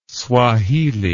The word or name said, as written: swahili